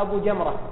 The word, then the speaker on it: أبو جمرة: كذلك بعض الرواة يقال له أو يكنى بأبي حمزة، وهناك من الرواة من يقال له أبو جمرة، في الكتابة شيء واحد لكن في النطق يختلف.